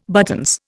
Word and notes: buttons: Indian

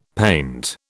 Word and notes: paint: British English

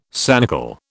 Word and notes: sanicle: British English

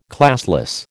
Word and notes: classless: American English

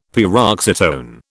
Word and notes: paroxytone: British English

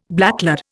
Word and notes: blacklead: Indian

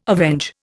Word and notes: avenge: Indian

